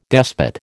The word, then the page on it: despot: American English